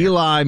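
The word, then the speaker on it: Ely: Eli, Minnesota, I believe is how you say it.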